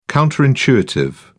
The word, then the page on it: counter intuitive: British English